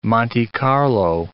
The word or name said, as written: Monte Carlo